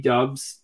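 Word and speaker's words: Doubs: Romeo Dubs, I mean, like, and again, am I not pronouncing his last name right? – Cause Dubs, I think that's how you say it, actually. Dubs.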